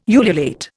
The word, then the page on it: ululate: Indian